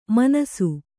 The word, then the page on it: ಮಿಣ್ಣಿಸು: miṇṇisu